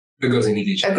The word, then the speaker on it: Ngozi Adichie: I listened to the TED Talk, I always get her name wrong, Chimamanda Ngozi Adichie.